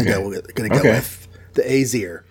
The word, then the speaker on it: Aesir: It's spelled A-E, but it's like a combined A-E. – Right. – S-I-R. And normally when I see an A-E in an ancient word, I tend to go with I because that's the Roman Greek version. But this is Norse, so different pronunciation rules. And he says it's Aesir.